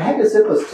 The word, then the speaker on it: Hegesippus: There's a very early Christian named Hegesippus. gets quoted by some of the early guys. That's if you're taking notes, his name is spelled H-E-G-E-S-I-P-P-U-S, Hegesippus.